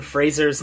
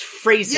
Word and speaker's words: Fraser: Well, I was wondering though, because that's how I know that Brendan Fraser's name is pronounced that way, because he said, and it's pronounced Fraser. – Yeah. – If you pronounce it Fraser, I know where you live.